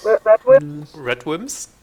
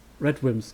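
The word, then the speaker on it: RADWIMPS: Und der Soundtrack hier von der Band Rod Dims, glaube ich, spricht man das aus? Rod Dims? – Red Wims? – Red Wims, genau.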